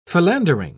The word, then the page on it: philandering: British English